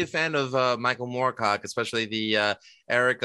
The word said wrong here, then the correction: Elric: of however it's pronounced, Elric, the albino owner of Stormbringer, the Black Blade.